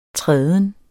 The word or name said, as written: træden